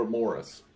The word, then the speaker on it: Maurice: Or Morris, even though it's spelled like Maurice.